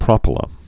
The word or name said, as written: propyla